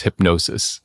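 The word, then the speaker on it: Hipgnosis: I think it's pronounced hypnosis. It's H I P G N O S I S, which is really just a remarkable story.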